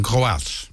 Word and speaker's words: Kroaats: In het Kroatiaat? – Het is gewoon Kroaats.